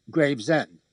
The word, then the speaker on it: Gravesend: Gravesend. – Oh, okay, That's how they say it in Brooklyn.